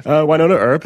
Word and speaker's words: Wynonna Earp: You know, they went over a Buffy the Vampire Slayer episode that they created themselves, and she's got her own series, Wynonna Earp, that's just... did I butcher the name? – No, that's pronounced? Perfect.